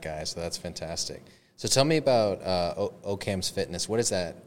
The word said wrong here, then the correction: Occam's: No, but it's okay. it's Occam's Fitness.